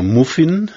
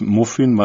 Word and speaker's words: Muffin: Das heißt nicht etwa Muffin nach deiner Lesart, sondern das spricht man wirklich Muffin aus.